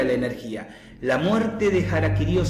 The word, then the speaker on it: harakiri: Por eso los shintoístas, los japoneses que practican la religión shinto, cuando se suicidan ritualmente se hacen el sapuku o harakiri, que se pronuncia harakiri,